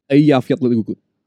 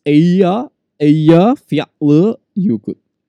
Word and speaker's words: Eyjafjallajökull: Eyjafjallajökull. – Holy shit. – No, sorry, that's Greenland. Nah, it's in Greenland. It's Eyjafjallajökull. – That's damn cool. – Eyjafjallajökull.